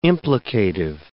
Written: implicative

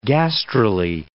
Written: gastrulae

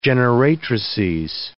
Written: generatrices

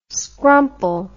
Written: scrumple

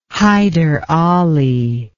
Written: hyder ali

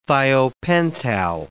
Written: thiopental

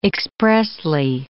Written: expressly

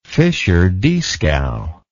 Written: Fischer-Dieskau

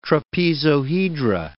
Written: trapezohedra

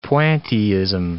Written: pointillism

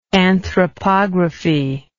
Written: anthropographic

